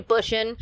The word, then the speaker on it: Busan: So we'll first start off with The Train to Busan,